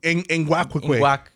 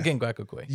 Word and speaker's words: Ngakoue: Ngakoue. – Yes. Yeah. Ngakoue.